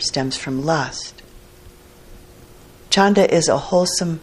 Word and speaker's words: chanda: The next wholesome factor of mind is wholesome desire, chanda, or khanda, it's sometimes pronounced in Pali.